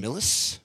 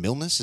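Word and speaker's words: Milius: One I like was, I'm a big fan of John Millis, right? Which his name is sometimes pronounced John Milnes, as though he has an N,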